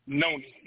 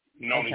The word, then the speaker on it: noni: You say Nani or Noni? How you spell it? – Noni. Noni okay.